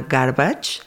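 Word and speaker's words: Garbicz: Una es que fui el año pasado a un festival en Polonia que se llama Garbage. Eh, se escribe Garbix, ¿no?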